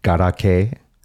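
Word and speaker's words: garake (ガラケー): How do you say flip phone in Japanese? – Paka paka? – Paka paka. Or do you know garake? – Oh, garake. Yes, garake.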